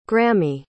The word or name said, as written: grammy